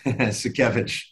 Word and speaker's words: Sakiewicz: Sakiewicz.